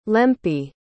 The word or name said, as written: lempi